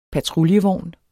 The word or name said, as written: PATRULJEVOGNE